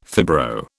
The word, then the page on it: fibro: British English